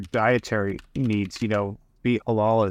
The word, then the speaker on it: halal: There was a big proclamation that was made where Bitcoin is now, I think it's halal.